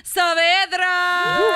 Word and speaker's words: Saavedra: Please welcome Natalia Saavedra.